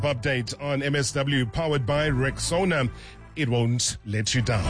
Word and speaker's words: Rexona: Now, first things first, it's Rexona, just like Rexona. Yeah, say it right and say it proud.